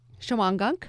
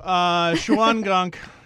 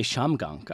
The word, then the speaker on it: Shawangunk: Thank you. Shawangunk. You know, I'm 50-50 on that word. – I think some people say Shamgunk. I think they don't even pronounce the whole thing. – Or Shangum.